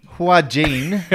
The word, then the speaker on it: Joaquin: But Joaquin. – Joaquin. – Joaquin Phoenix. – The J is silent.